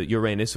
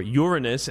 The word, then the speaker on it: Uranus: Up until now, NASA has never paid too much attention to Uranus, or Uranus, as it's supposed to be pronounced.